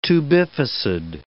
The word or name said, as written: tubificid